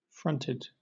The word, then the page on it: fronted: British English